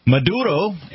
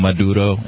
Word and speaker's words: Maduro: Maduro,